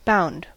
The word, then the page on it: bound: /ˈbaʊnd/; [baʊnd]